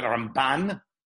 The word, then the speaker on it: Ramban: So when we talk about RambaN, soft. You talk about the RambaM, it's like pebbles and bam bam, RambaM. RambaN versus RambaM.